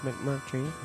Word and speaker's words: McMurtry: Sorry, I was pronouncing it incorrectly. It's McMurtry.